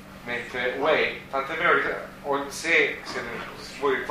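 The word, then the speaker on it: Wei (位): Questo è un altro carattere, un altro classificatore che si pronuncia Wei. Wei, W-E-I. – Quale sarebbe il quarto? Ed è Wei, W-E-I, quarto d'ora.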